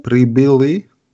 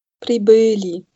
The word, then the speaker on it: прибыли: Da gehe ich quasi, das I, das wird hinten im Rachen gebildet. Pribili. – Pribili, genau. Also dieses, dieses Zeichen da zwischen B und L, dieses Ö, ähm, da wird jetzt die Betonung draufgelegt. gelegt.